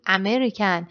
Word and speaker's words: American: مثلا توی کلماتی مثل arrive میگیم، American میگیم، American، ما این رو ا تلفظ میکنیم در صورتی که اصلا ا نیست.